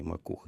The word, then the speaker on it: Макух: Ну, всі навіть родичі говорять Макух. Але коли в приватних розмовах, коли ми зустрічалися уже за столом, з багатьма я дружу, то і дружина підтвердила, що Василь все ж таки волів бути наголос як по українській мові і Макух.